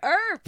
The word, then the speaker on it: Earp: So our friend Kaylee Holmes, Kales from the Crypt, says Winona Yarp. Yarp? Arp? – Winona Earp? – Earp? Earp! – Like Wyatt Earp? – Wyatt! Oh, that makes sense.